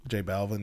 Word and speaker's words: J Balvin: How do you say his name? Balvin. – J Balvin.